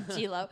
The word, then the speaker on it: Giallo: I keep fucking up the pronunciation. Giallo. Giallo. Skilo. No, Giallo.